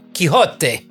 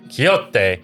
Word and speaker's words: Quixote: Kioxti? Coxti? – Where are you reading this? – Right here. – Quixote. – Okay. Quixote.